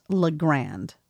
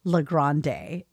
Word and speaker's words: La Grande: is pronounced La Grande, not La Grande. It's not named after Ariana Grande. The E is silent.